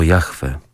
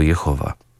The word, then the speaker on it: Jahwe: Przez niektórych na przestrzeni wieków błędnie wymawiany jako Jehowa. Błędna wymowa powstała stąd, że w pierwotnej pisowni hebrajskiej zapisywano same spółgłoski, a czytający właśnie dodawał do nich odpowiednie samogłoski.